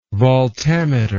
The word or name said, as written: voltameter